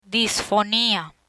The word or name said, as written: disfonia